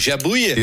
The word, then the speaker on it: Jabouille: Jean-Pierre Laboulier. Ik zeg het waarschijnlijk niet goed. Jabouille. Ja.